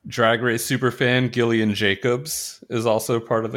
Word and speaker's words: Gillian: Gillian with a hard G. – Gillian.